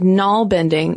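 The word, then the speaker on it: nalbinding: And I gotta tell you, Caroline, side note, when I first read the word knoll bending, which, listeners, is spelled N-A-L-B-I-N-D-I-N-G, I immediately thought of narwhals. And so in my mind, when I hear knoll bending, they're using their little horns to knit.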